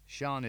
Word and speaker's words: Sian: Sian is spelt S I A N.